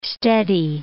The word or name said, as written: steady